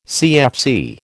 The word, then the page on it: CFC: American English